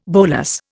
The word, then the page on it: bolas: Indian